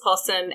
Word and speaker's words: opossum: And in America they were called opossums. And someone's like, oh no, it's like based on a like a native, you know, pronunciation word. So it is actually pronounced possum and we got the word from them.